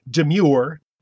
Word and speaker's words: demur: In other words, they're actually saying the word demure, D-E-M-U-R-E. – Okay, so let me get this straight. What she's talking about is the word demur, D-E-M-U-R. – Right. – But she's saying she hears people say pronounce it demure as if it were the word demure, D-E-M-U-R-E. – And Fletcher, let's just throw this out.